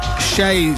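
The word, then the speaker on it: Chasez: She's an artist name pronounced Shaze. – Shaze. – Is it? It is Shaze.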